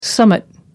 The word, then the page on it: summat: American English